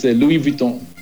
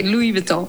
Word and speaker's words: Louis Vuitton: Yeah, so the pronunciation is Louis Vuitton. – Okay, Louis Vuitton.